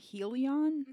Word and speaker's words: Helion: Helion. – No, it's like Helios.